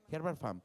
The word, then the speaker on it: Herbert Farm: Entonces, este, dio con una empresa que se llama Herbert Fram Nada más, la, la pronuncio medio raro, pero aquí me ayudan, ¿verdad? Herbert Fram así nomás, Farm, como de familia, Herbert Farm.